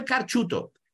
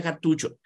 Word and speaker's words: cartucho: no sabe decir, no de decir carchuto, dame el carchuto. No se dice carchuto, se dice cartucho.